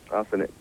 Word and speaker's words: Azeneth: A-Z-E-N-E-T-H. – A Zenith? – Asenet. – What is it? – Asenet. – Asenet? – Yeah. The H is silent.